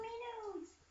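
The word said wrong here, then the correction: Minun: It's Minum. That's how you pronounce it.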